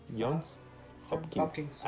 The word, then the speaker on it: Johns Hopkins: O sea, tengo aquí una de, de, 1920 un psicólogo en la universidad, John Johns Hopkins o Hopkins, Johns Hopkins.